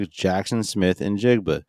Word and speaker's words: Smith-Njigba: And round one, the Seahawks took Jaxon Smith-Njigba.